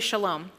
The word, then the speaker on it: shalom: Everybody say shalom. – Shalom. – Say it like you know Hebrew. Shalom. You just say it the same way. Shalom is this beautiful Hebrew word that is translated as peace.